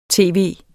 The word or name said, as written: tv.